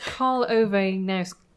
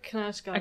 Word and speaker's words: Knausgaard: and I'm now going to fail to pronounce it, Nausgaard? – We decided it's Karl Ove Nausgaard. – I've heard Knausgaard. – Okay, let's go overboard on the kind of Norwegian thing, because that'll sound cooler.